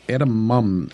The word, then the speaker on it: edamame: We mentioned, and I don't know if I ever say it right, edamame. – Okay, there we go. But I think I always want to put edamame. A B in there, so it's edamame.